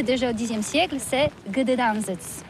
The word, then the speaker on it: Gdańsk: Oui, c'est pas très facile pour des étrangers, mais remarque bien que la langue polonaise, c'est la langue où on prononce chaque lettre qu'on écrit. C'est pourquoi on dit Gdańsk. – Gdańsk.